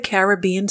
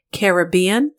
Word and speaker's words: Caribbean: in the Caribbean Sea of the Atlantic Ocean. I just wanted to mention here that Caribbean or Caribbean are both correct pronunciations according to Merriam-Webster's dictionary.